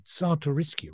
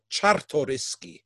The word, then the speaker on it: Czartoryski: The painting is best known as the Czartoryski Raphael. Now, have I pronounced that correctly? – No, of course not. No, not even close. Zs are pronounced as Hs, so Czartoryski.